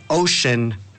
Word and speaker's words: ocean: Pues ya que estamos con el tema del océano, pues la palabra de hoy va a ser precisamente ocean. ¿Qué significa océano? Es muy similar, ¿cómo se deletrea? Se deletrea O-C-E-A-N, pero se pronuncia ocean.